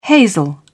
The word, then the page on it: hazel: British English